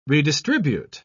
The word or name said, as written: redistribute